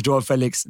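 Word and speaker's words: João Félix: But the Joao Felix is Joao Felix, not Felix.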